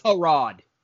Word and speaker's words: Tyrod: Tyrod is how you're supposed to pronounce it. – Tyrod Taylor. Yeah, I don't understand how Tyrod when it's a Y.